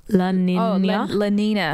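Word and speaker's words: La Niña: And then the rest was kind of, I always say it wrong, but is it La Nina? – La Nina.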